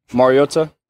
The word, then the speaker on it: Mariota: Mariota.